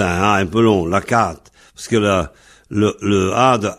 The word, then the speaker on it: lakaat: Lacat s'écrit L-A-K-A et A-T. A-T ou A-D, selon les orthographes des uns et des autres. Mais ici, en finale, ça se prononce T, donc lacat. L-A-K-A-A-T. Alors, l'accent est sur l'avant-dernière syllabe, c'est-à-dire avant la finale at, donc lacat. Ce qui fait qu'en fait, l'accent est sur la finale, lacat.